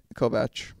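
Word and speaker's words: Kovač: Kovac.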